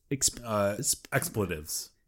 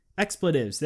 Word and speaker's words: expletives: So this book is called Fake Swears, Filthy Explicatives. Is that how you say it? – Expletives. – Expletives. There it is.